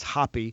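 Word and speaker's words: Hoppe: David Hoppy, and by the way, we just checked on the pronunciation. Apparently it's Hoppy.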